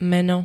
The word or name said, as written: meno